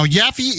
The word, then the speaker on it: Yaffe: Now, Yaffe is my last name. It's spelled Y-A-F-F-E-E.